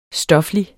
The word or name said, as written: stoflig